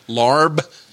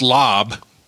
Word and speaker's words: larb: But L-A-A-R-B. It's pronounced lob, but there's an R in there in the English translation.